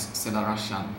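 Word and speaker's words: Zelarayán: If you're either an armchair scout or a football manager player, the surname is spelled Z-E-L-A-R-A-Y-A-N. and it's pronounced in Argentine Spanish, Celarayán.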